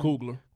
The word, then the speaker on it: Coogler: What's how you say his name? – Coogler.